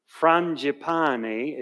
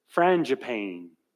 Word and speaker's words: Frangipane: The author is Francis Frangipane, if you want to pronounce it the way the French do, but it's Frangipane for doing it American style.